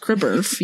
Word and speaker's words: CREBRF: So C R E B R F, which is pronounced Kreberf. yes.